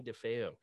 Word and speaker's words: Defeo: Johnny Defeo.